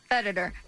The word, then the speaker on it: Federer: Federer.